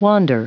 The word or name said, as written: wander